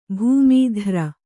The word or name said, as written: ಭೂಮೀಧ್ರ